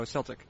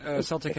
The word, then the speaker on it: Celtic: Is it not pronounced Celtic? – It's not. No, Celtic. Celtic has many.